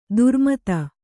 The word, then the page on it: ಧರ್ಮೇತ: dharmēta